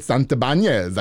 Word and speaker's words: Santibanez: So I want everybody to give a warm welcome to my boy, David Santibanez.